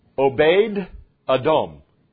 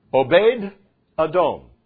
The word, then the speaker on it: Obed-Edom: Now, that name is actually pronounced Obed-Edom. Obed-Edom. Can you say that with me? Obed-Edom. Obed-Edom. I obeyed Edom. It sounds like that. It's one way to remember it.